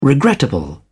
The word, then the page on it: regrettable: British English